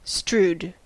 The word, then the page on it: strewed: /struːd/